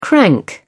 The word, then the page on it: crank: British English